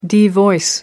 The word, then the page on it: devoice: American English